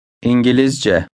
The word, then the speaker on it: İngilizce: İngilizce. C. C. Giliz. Gilizce. İn. İngilizce. İngilizce.